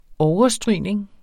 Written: overstrygning

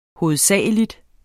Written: hovedsagelig

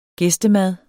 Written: gæstemad

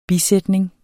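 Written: BISÆTNING

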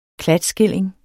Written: klatskilling

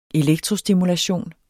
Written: elektrostimulation

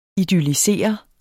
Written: idyllisere

